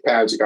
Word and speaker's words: Padgett: If I remember correctly, you were running against, and I'm dating myself big time, wasn't it Steve Padgett? Am I pronouncing that right? Was he the Democrat nominee? I can't remember. – Primary, you're right. It was Steve Padgett out of Jacksonville.